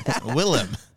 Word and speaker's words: Willem: Can't even say his name right. – What is it? – Willem. – Is it Willem? – Yeah.